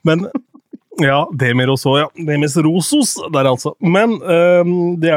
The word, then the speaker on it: Demis Roussos: Eh, vi hadde jo faktisk Jeg tror jammen navnet uttales Demis Rosos. Altså. Demis Rosos, ja.